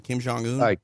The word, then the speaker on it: Kim Jong-un: Kim Jong, the North Korean leader. Am I saying his name right? I always have an issue with his name. – Yeah, I think you got it right. Kim Jong-un.